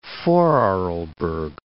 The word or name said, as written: Vorarlberg